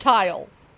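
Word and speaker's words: Tyle: They named him Tile. Well, it's T-Y-L-E. – Yeah, but still Tile. It's pronounced Tile.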